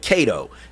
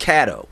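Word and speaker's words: Catto: Really, they called it Cato. His name is pronounced Caddo, but they called it the Cato School.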